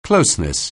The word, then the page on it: closeness: anglais